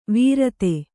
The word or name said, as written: ವಿರತೆ